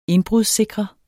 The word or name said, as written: indbrudssikre